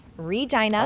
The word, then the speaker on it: Regina: Yes, I said it right. Regina. – I would hope so if you're from this. I did, yes. People are like, why don't they call it Regina?